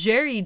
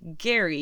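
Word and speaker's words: Gerry: And yes, I did confirm through listening to podcasts, it is Jerry Duggan and not Gary, even though it's a G. So, go figure.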